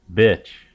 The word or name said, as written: bitch